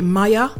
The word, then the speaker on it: Mia: Number seven is Mia, M-I-A. You could pronounce it Maya or Mia.